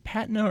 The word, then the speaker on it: patina: Is it patina or patina? – Patina.